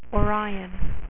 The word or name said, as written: orion